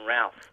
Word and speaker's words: Routh: Well, it's actually pronounced Brandon Routh. – Oh, Routh. – Like South,